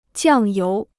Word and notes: 酱油: jiàng yóu

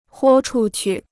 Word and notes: 豁出去: huō chu qu

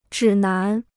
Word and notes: 指南: zhǐ nán

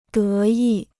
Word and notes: 得意: dé yì

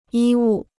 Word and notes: 医务: yī wù

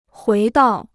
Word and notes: 回到: huí dào

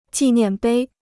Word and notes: 纪念碑: jì niàn bēi

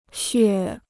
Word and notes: 血: xuè

